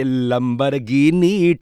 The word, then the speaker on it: Lamborghini: You know, best part about this song is that agar aap isko proper pronunciation ke saath gayenge Lamborghini to itna maza nahi aayega. You got to say Lamborghini theka hona chahiye.